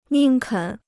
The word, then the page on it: 宁肯: nìng kěn